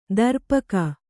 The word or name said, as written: ದರ್ಪಕ